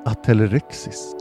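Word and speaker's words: Atelerix: Ett släkte är Atelerexis. Förekommer huvudsakligen i Afrika. Och i nära släkt med arterna i Erinaceus. Bland Atelerex, så uttalas det, förekommer afrikansk pygmeigelkott och Atelerex frontalis som lever i södra Afrika från Angola och Zimbabwe till Sydafrika.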